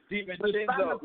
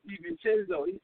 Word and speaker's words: DiVincenzo: It's DiVincenzo. Look, he's so bad. I don't even know how to say his name. It's DiVincenzo.